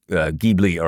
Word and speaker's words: Ghibli: Even like the Studio Ghibli, or what is that right?